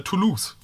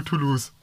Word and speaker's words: Toulouse: Und die Manufakturstelle ist in Toulouse, wo ich übrigens auf Austausch war. – Finde ich auch lustig, dass eine französische Stadt einfach so ausgesprochen wird, als wenn man im Englischen verlieren sagen würde.